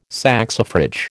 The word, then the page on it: saxifrage: American English